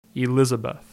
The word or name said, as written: Elizabeth